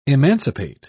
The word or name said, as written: emancipate